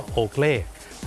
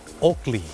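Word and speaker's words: Oakley: โอเกลแต่เจ้าของภาษาไม่ได้ออกเสียงว่าโอเกลครับเขาออกเสียงว่าโอกลี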